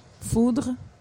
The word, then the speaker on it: foeder: Foudre? – Yeah. So what is the purpose of a foeder? – So I always go with the Dutch pronunciation of foeder because Peter Boekhart is like yes.